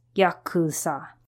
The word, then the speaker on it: Yakuza: These three numbers, 8, 9, 3, when read in a specific manner, are pronounced Yakuza.